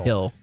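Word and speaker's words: Gil: The pitcher, it's G-I-L, but I think it's pronounced Hill. – Hill.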